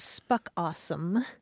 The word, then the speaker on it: spookasem: There's a South African term that's spelled S-P-O-O-K-A-S-E-M. And it's pronounced spuck awesome.